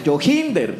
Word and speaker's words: Joginder: Dice, Joginder se pronuncia con acento en la ji, ya que los escucho con la duda de cómo pronunciarlo. Entonces sería Joginder.